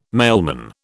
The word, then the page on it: mailman: British English